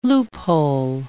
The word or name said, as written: loophole